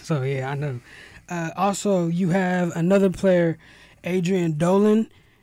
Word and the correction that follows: Aiden Dolan: I think that's how you pronounce it. – Aiden Dolan.